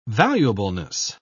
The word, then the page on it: valuableness: British English